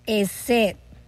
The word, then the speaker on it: hesed: La palabra misericordia aquí viene del hebreo hesed. Y se escribe, ¿verdad?, H-E-S-E-D.